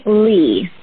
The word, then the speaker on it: Leah: Because I keep saying Amy Leah, and I want to say Amelia. Or I know. It's a tough one. It's actually Amy Lee, which is, it escapes everyone.